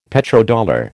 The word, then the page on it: Petrodollar: American English